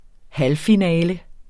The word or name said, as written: halvfinale